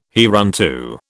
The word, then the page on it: hereunto: British English